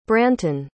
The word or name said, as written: branton